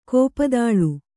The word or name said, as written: ಕೋಪದಾಳು